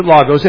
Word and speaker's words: logos: Here's a Greek word, logos, or logos. That's how we pronounce it, logos.